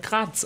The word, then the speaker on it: Graz: Aber ich habe recherchiert, die Stadt in da Österreich wird halt Graz ausgesprochen.